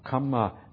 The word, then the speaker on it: kamma: Kamma has only one M, and it has a long A, an A with this little bar across it at the top.